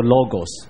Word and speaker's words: logos: 事实上神的道希腊文里面用 logos 这个字，就是后来演成 logic 演成逻辑的同一个字，你知道吧？我会不会讲太快了？好，新约是用希腊文写的，新约那个希腊文里面道这个字哈，读作 logos，而 logos 就是后来演成 logic logic 这个逻辑这个字。